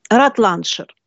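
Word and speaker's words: Rutlandshire: Но сейчас она называется, и здесь написано, Ратландшир. Обратите внимание, что Бодрон называет английское графство старым именем Рутландия, земля рутов. А вот современное произношение «ратландшир», несмотря на написание слова через прежнее «рут», звучит уже как «рат».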